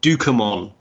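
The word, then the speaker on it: Ducommun: But then I watched the extras and it's Rick Dukemon. That's how you say it. Okay. So just think Pokemon, but slightly different and spelt completely different, and that's your guy.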